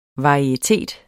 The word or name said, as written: varietet